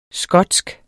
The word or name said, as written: skotsk